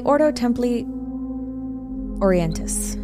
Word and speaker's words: Ordo Templi Orientis: called the Ordo Templi Orientis.